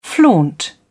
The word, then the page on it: flaunt: British English